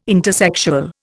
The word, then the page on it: intersexual: Indian